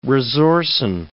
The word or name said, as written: resorcin